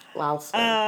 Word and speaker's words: Gloucester: Gloucester. It's spelled like that, yes, Gloucester.